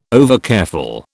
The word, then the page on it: overcareful: British English